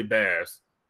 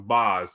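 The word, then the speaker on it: Bas: In addition to that, I listened to the new, uh, and I feel like I say this incorrectly because I want to say Bass, but Bass. In the yeah, in the music video, I heard him referred to as as something I don't pronounce it, so it's Boz.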